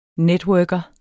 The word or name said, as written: networker